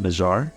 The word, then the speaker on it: Najar: Dr. Natasha, am I saying this right, Najar? – Yes.